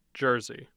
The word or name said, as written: jersey